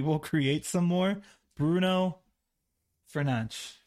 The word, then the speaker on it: Fernandes: Bruno Fernandes. – That's how they say it. I think that's the Portuguese way, Phil, Spanish way.